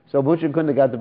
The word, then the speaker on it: Muchukunda: It's not Muchikunda. Sometimes people pronounce this demon's name wrong. They say Muchikunda. It's Muchukunda.